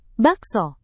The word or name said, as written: bakso